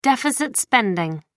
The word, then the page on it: deficit spending: American English